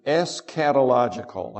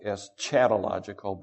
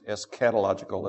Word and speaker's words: eschatological: The first, if you're taking notes, the first goal is eschatological. I know it looks like eschatological, but it's pronounced eschatological as if it was a K and not a CH. Eschatological.